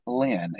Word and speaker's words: Len: It's Johnathan Lynn.